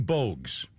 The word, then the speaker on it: Bogues: Muggsy Bogus, or Bogue. Is it Bogues? Muggsy Bogues. – As I pronounce, Muggsy Bogues, 59 years old, former NBA dunker who is 5'3".